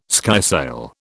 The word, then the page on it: skysail: British English